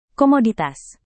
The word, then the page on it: komoditas: Indonesian